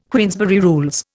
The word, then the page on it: Queensberry Rules: Indian